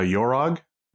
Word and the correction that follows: Yon-Rogg: right? – Okay. – I think, am so, I saying that right? – Yeah. Sure. Why not? so, yeah. – We'll just, yeah, it's Yon-Rogg.